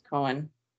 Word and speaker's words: Cohan: I think it's pronounced Cohen. – Cohen? – I think.